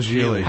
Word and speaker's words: Gigli: Oh, Geely? – It's called Geely. – How do you spell that? – G-I-G-L-I.